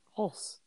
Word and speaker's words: Hulce: Is it just Hulce? – Hulce. Yeah. – Hulce. – Hulce. – Oh, I always made it fancy. I called him Hulce. – Hulce? – Hulce.